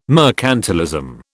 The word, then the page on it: mercantilism: British English